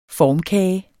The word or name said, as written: FORMKAGE